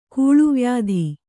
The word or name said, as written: ಕೂಳುವ್ಯಾಧಿ